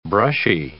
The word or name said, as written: brushy